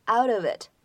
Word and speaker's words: out of it: 再来看一下末尾的地方，out of it 这样的三个单词呢出现在一起，当中呢会有两处连读，而且这个 out 末尾这个 t 呢，它是一个闪音的处理，所以呢在美式英语当中，这个 out of it 我们呢可以读成是 out of it。